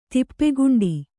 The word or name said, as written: ತಪ್ಪುಗಂಟು